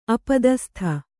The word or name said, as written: ಅಪದಸ್ಥ